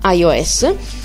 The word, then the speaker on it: iOS: Nel caso dell'iPhone appunto questo sistema si chiama iOS, scritto iOS, appunto si pronuncia iOS, e ogni tot tempo esce un aggiornamento appunto di questo sistema operativo.